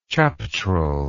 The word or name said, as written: chaptrel